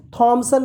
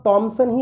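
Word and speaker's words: Thomas: लगेगा तब आप कभी भी थॉमसन नहीं बोलेंगे तब आप टॉमसन ही बोलेंगे तब आप थॉमस थॉमस या थॉमस नहीं बोलेंगे आप टॉमस बोलेंगे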